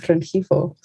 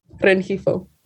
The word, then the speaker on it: Rengifo: It's Rengifo. So Valeria René Rivera Rengifo. – Wow. That is even, it gets better. What was the last name again? The very last name? – Rengifo. – Spell that. – It's R-E-N-G-I-F-O.